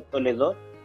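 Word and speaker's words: Toledot (תולדות): La palabra con que se encabezan todas las genealogías en el libro de Génesis y en otros libros del Antiguo Testamento es una palabra hebrea que se pronuncia Toledot, y se traduce al griego como Génesis.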